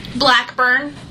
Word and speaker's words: Blackburn: Blackburn. – B-L-A-C-K-B-U-R-N? – Yes, sir.